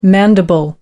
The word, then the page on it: mandible: American English